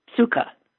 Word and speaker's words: Sukkah: S-U-K-K-A-H. – Sukkah. – Sukkah. That's what I thought. I had to be sure. Now, why am I asking this question? Because tomorrow I'm going to be at the Sukkah Mobile.